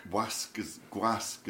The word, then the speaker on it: gwasg: Gwasg is the Welsh word for press. But you have to pronounce that G on the end, otherwise you refer to the servant, the manservant, or the slave. So you have to say Gwasg Gregynog, which is, when I joined the press, that's what it was called, was Gwasg Gregynog.